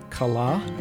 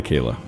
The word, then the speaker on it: Kallah: How do you pronounce this? K-A-L-L-A-H. – Kala? – Okay, she goes by Kayla. But it's a Hebrew name.